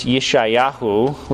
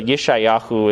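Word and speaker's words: Yeshayahu (ישעיהו): So Hebrew, the Hebrew term is also the name Isaiah, which you would pronounce Yeshayahu, which doesn't sound quite like Isaiah, I know, but that's kind of how some of the Hebrew names are. And so Yeshayahu is how you would pronounce that. And it means, the name means Yahweh saves.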